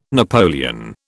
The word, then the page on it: napoleon: British English